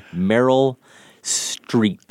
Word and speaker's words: Meryl Streep: We see her getting some love from the Academy, Meryl Streep.